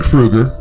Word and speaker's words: Krueger: So my name is Ken Krueger. Krueger is spelled K-R-U-E-G-E-R.